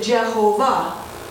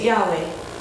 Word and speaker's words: Yahweh: Originally, we put vowels in here like that, and so we pronounced it Jehovah in the old King James. But now we put an A there and an E there and pronounce it Yahweh. But those are two different English attempts to say a Hebrew word that we don't know how to pronounce.